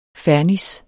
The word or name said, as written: fernis